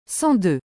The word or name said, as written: 102